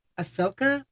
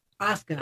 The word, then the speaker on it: Asuka: You have an effect with Ahsoka, or is it pronounced Asuka? Asuka, I think.